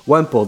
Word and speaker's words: Wemple: I'm here with Cayden Wemple.